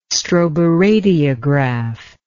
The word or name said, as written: stroboradiograph